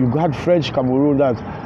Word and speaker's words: Cameroon: Cameroon.